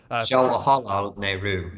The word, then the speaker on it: Jawaharlal Nehru: Jawaharlal Nehru is the pronunciation, or just call him Jawahar or Pandit, as he was teacher in Sanskrit, Pandit.